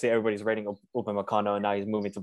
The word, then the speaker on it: Upamecano: Upamecano.